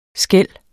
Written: skel

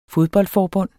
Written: FODBOLDFORBUND

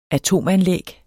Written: atom-

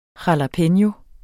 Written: JALAPENO

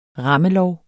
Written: rammelov

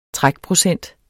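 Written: TRÆKPROCENT

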